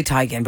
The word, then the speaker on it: Teigen: I didn't see this recent picture, but you know, Chrissy Teigen, it's actually Teigen, but we say Teigen and she just lets us say it like that.